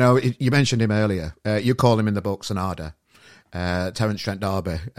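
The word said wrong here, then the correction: Sananda: Sonanda. – Sorry, my pronunciation's bad. So it's Sonanda.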